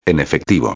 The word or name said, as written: en efectivo